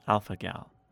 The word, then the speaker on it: alpha gal: The first one, alpha gal. That G makes a hard G sound. Alpha gal.